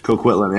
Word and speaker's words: Coquitlam: Coquitlam. Did I say that right? – Coquitlam, yeah. Yeah, okay.